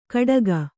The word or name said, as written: ಕಡಗ